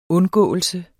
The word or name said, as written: undgåelse